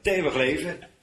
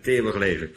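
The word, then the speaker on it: 't eeuwig leven: Met een T begint dat. T eeuwig leven. – Inderdaad. – Eigenlijk T eeuwig leven, hè? Zo spreek je dat uit. T eeuwig leven.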